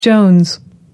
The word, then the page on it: jones: American English